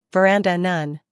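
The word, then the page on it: veranda: uk vəˈrændə; en-us vəˈrændə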